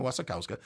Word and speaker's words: Wasikowska: This weekend, we're featuring Maps to the Stars, David Cronenberg's newest movie, with Julianna Moore, Mia Wasikowska... I didn't say that right. Yeah, that's right. Mia Wasikowska.